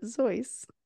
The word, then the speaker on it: Seuss: If you're calling him Seuss, he pronounces it Zois."